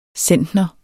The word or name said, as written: centner